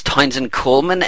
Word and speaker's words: Townsend Coleman: So yes, that's the voice of Michelangelo, and Michelangelo, alter ego, is Townsend Coleman.